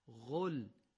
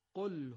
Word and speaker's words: قل: بنابراین ما چی باید بگیم؟ غل یا گل یا قل؟ قطعا سومی درسته. پس میخونیم قل هو الله احد.